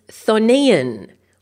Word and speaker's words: chthonian: Okay, it's thonian or thonic. Now let me spell it for you. Thonian is actually C-H-T-H-O-N-I-A-N, or thonic is C-H-T-H-O-N-I-C.